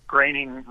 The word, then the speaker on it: Groening: Yeah, what had happened was Matt Groening rhymes with complaining, but not really.